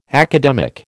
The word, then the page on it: academic: American English